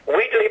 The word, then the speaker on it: Wheatley: Wheatley Press. – Weekly? Spell it, please. – W-H-E-A-T-L-E-Y. – Oh, like wheat. Wheat.